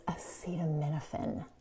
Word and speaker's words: acetaminophen: And one more thing, it's acetaminophen. That's how you say it.